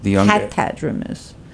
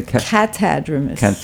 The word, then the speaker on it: catadromous: That's called catadromous. How do you say? – Catadromous. – Catadromous. Right. Okay.